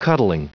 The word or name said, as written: cuddling